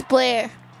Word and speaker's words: Blair: I just wanted to let you know our friend Blair is here, and her name rhymes with Claire. – Forgot to tell you that. – Hello, it's Blair.